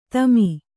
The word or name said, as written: ತೇಮ